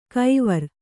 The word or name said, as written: ಕೈವಾರ್